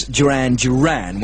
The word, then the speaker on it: Duran Duran: Thank you very much for a couple of people who rang up and I didn't realise that I said it, but I mispronounced the name. I said Darren Darren and Planet Earth at number 26, when of course it should be, as everybody knows, Duran Duran.